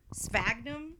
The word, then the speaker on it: sphagnum: You can also put some, and I'm always really bad at trying to pronounce this, sphagnum moss. – Good. – Sphagnum? – Yeah, sure. – Okay, we're going to go with S-P-H-A-G-N-U-M moss.